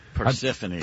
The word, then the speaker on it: Persephone: Is it Persephone or Persephone? – Persephone. – Persephone, that's because they spell it. You know, it's like, who's the other famous female character that ends in Persephone, something Persephone is spelled like phone.